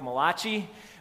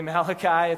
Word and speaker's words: Malachi: It's the one that if you tried to pronounce it, you know, English-wise, it looks like Malachi, but it's really Malachi.